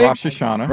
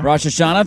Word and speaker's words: Rosh Hashanah: He saw Jesus in a divine vision returning to earth during this year's, is it Rosh Hashanah? How do you say that? Did I say that correctly? – Rosh Hashanah. – Rosh Hashanah, thank you.